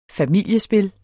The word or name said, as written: familiespil